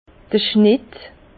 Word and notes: d'r Schnett: Bas-Rhin; Herrlisheim

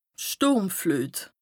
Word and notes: d' Stùrmfluet: Bas Rhin; Strasbourg

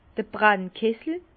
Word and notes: d'r Brannkessel: Bas Rhin; Herrlisheim

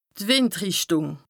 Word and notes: d' Wìndrìchtùng: Bas-Rhin; Strasbourg

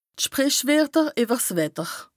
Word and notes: d' Sprìchwerter ìwwer 's Wetter: Bas-Rhin; Strasbourg

